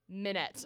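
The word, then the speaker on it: Minnette: Dylan, Meninette. Meninette? – Try again. – Minette. It's just Minette. I decided to add 14 more syllables to that, so no worries.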